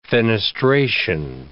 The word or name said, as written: fenestration